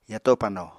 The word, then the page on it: yato pano: apatani